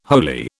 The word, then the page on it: holy: British English